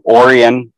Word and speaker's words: Orion: But a year ago, maybe a year ago, something like that, I moved to Orion, Illinois. Orion is spelled like Orion, but it's pronounced Orion.